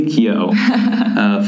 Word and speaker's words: Keough: And one day in his apartment complex, he sees Sarah, who is Riley Keough. – Yeah, I don't know how to pronounce her last name. Riley Keough.